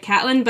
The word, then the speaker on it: Caitlin: And like when I read How to Build a Woman, she does this little thing about how her name is spelled Caitlin, but she likes to pronounce it Catlin.